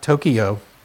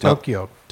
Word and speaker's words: Tokyo: It's not Tokyo. – It's Tokyo. Tokyo. – T-O-K-Y-O. Tokyo. – Tokyo. – Fun thing about the Japanese language, every syllable has the same emphasis. That's why it sounds like.